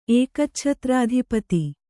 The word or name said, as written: ಏಕಚ್ಛತ್ರಾಧಿಪತಿ